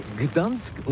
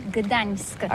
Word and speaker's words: Gdańsk: Ça s'écrit G-D-A-N-S-K. On dit Gdansk ou Dansk, on prononce le G ou pas? – Oui, c'est pas très facile pour des étrangers, mais remarque bien que la langue polonaise, c'est la langue où on prononce chaque lettre qu'on écrit. C'est pourquoi on dit Gdansk.